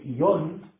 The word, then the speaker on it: ion: Platón habla, en otro momento de sus obras, en un dialoguito que se llama el ion, I-O-N, acento en la O,